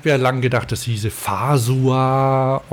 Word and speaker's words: Fazua: Weiß nicht, ob ich das korrekt ausgesprochen habe. – Fazua, klar. – Fazua. Naja, Fazua. Im Fränkischen würde man zu sagen. Aber der Bayer sagt Fazua.